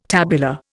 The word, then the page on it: tabular: Indian